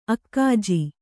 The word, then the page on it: ಅಕ್ಕಜ: kannada